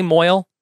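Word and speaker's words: mohel: Am I saying that correctly, Moyle? – Yeah, you're saying it right.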